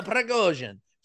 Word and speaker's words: Prigozhin: I mean, he gave a statement, and we're talking about I hope I pronounce the name right. Yevgeny Prigozhin. Yeah. He's a Prigozhin.